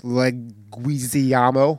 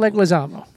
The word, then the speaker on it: Leguizamo: The leader, Scrooge, played by John Leguizamo. – John Leguizamo. – Leguizamo. – Leguizamo. – Are we adding an L? – That's just how they pronounce it.